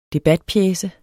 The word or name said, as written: debatpjece